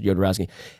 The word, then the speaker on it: Jodorowsky: If you have not seen the documentary Jodorowsky's Dune, it starts with a J, but it's pronounced Jodorowsky.